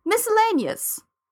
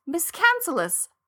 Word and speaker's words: miscellaneous: miscellaneous, which I always pronounce in my head as miscancellous.